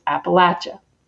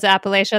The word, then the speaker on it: Appalachia: And I find it so interesting that even 100 miles outside of here, people don't know how to, we pronounce Appalachia.